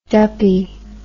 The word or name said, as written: duppy